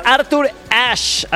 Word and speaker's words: Arthur Ashe: Oye, nos está escuchando nuestro querido amigo Ricardo Cázares, que me manda mensajito y nos dice: Pollito, es Arthur Ashe, así se pronuncia, y es el estadio de Estados Unidos en New York, se llama así.